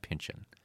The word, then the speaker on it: Pynchon: Wait, so the correct pronunciation is Pynchon, not Pynchon.